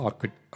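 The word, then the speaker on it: orchid: ஆர்கிட் அதாவது ஒரு flower இருக்கு இல்லைங்களா வைல்டு ஃப்ளவர் ஆர்ச்சிட்னு சொல்லுவாங்க சில பேர் ப்ரொனவுன்ஸ் பண்ணும்போது ஆர்கிட் அப்படின்வாங்க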